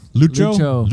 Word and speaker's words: Lucho: Luco Azcaraga. And I'm probably not pronouncing it. No, it's Lucio. – Lucho. – Ashkaraga. – Ashkaraga. Ashkaraga.